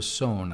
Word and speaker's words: Saône: All the Mâconnais is, as Larry mentioned, it's south, and it's south of the river Saône, I think it's pronounced. – Yes, S-O-N-N-E.